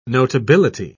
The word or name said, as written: notability